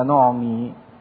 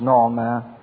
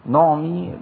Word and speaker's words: نعمي: والحقيقة ان قصة راعوث ونعمي هي النطق الصح للكلمة نعمي مش نعمة، نعمي بكسر الميم، هتلاقوا تحتيها كسرة، لان نعمي دي تعتبر رمز حلو للكنيسة اللي بتقدم المسيح لكل إنسان.